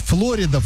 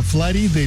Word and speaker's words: Флорида: Как правильно говорить, Флорида, Флорида или допустимы оба варианта? – Флорида.